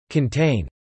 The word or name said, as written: contain